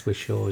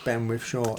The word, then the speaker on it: Whishaw: I think I've accidentally put an N in. I think you're right, it's Whishaw, isn't it? – Ben Whishaw.